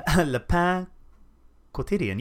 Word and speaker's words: Le Pain Quotidien: Le Pain quotidien.